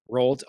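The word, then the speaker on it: Roald: I think that's how you say his name. Rolled. Okay. – Rolled. – I heard people say Rolled Ronald, Dahl. And I'm like, that doesn't look like how it's spelled.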